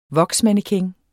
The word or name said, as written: voksmannequin